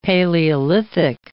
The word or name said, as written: paleolithic